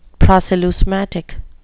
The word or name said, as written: proceleusmatic